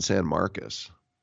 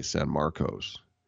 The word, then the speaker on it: San Marcos: Texas State is in San Marcos, which if you asked me to read it, I would say San Marcos. But in Texas, trust me, they pronounce it like Marcus Allen, San Marcos.